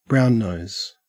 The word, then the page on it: brownnose: Australian English